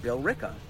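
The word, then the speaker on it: Billerica: No, it's fucking pronounced Bill Ricka.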